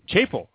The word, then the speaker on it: Chappell: Chappell. – All right, I got to get it right. Chappell. I got to go long A on the Chappell there.